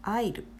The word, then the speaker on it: aisle: それから、アイル、 AISLE と書いてアイル、通路のことですね。飛行機に乗る時の通路側の席のこともアイルと呼びますね。これも S は、真ん中に来ている S は発音しません。